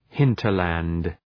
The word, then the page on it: hinterland: ‘hıntər,lænd